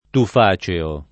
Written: tufaceo

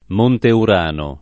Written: Monte Urano

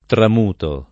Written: tramutare

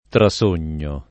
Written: trasognare

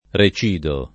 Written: recidere

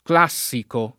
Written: classico

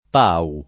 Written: Pau

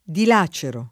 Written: dilacerare

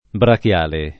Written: brachiale

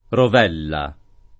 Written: Rovella

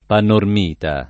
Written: panormita

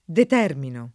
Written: determinare

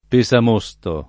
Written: pesamosto

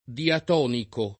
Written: diatonico

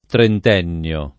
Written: trentennio

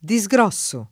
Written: disgrossare

